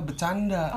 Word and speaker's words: Betjanda: Jadi bacanya adalah bercanda. – Oh, bercanda. – Kan TJ ejaan dulu oh, adalah iya, C.